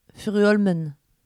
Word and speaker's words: Furuholmen: Bah, on ne dit pas le EN à la fin. – Furuholmen.